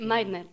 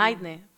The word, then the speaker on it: Meitner: Meitner, que no sabemos cómo se pronuncia bien. Porque... – Meitner, lo he estado buscando. – Meitner, porque era austríaca.